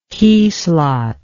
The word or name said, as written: keyslot